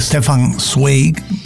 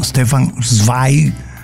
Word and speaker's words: Stefan Zweig: Y Stephen Zweig, otros pronuncian Stefan Zweig, pero él es muy conocido como uno de los grandes biógrafos del mundo.